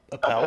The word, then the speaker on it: Appel: It was the the Stanford dude, uh, Mark Apple uh, Appel. Appel, Apple yeah.